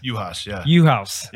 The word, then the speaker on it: Juhasz: Juhasz.